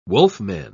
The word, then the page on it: wolfman: British English